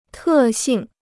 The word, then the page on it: 特性: tè xìng